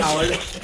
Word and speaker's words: Howard: So right now I have Leonard Fournette and Jordan Hayward. And I think Jordan Howard. – All right, I keep pronouncing it wrong, but anyways, listen.